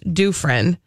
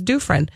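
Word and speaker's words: Kufrin: So last night was finally the premiere of The Bachelorette, featuring our very own, Minnesota's own, Prior Lake's own, Becca, and I've learned her last name is pronounced Dufresne. Yes, I've been listening to a podcast, the Anna Faris Unqualified podcast, where that is the first question they ask. How do you say your last name? And she says Dufresne.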